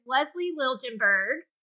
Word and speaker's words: Liljenberg: Our exciting guest for today is Lezlee Liljenberg. Did I say that right? Perfect. yes.